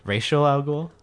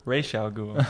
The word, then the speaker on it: Ra's al Ghul: Racial al Ghul? – Ra's al Ghul. That's how you pronounce it, actually.